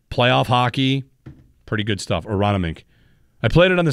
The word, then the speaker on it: Aronimink: Aronimink. I've been practicing how to say it. The PGA Championship. – Oh, Aronimink. – Aronimink.